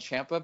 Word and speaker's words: Ciampa: It's actually Gargano and Ciampa because that's how they pronounce their own fucking name.